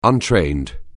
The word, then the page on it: untrained: anglais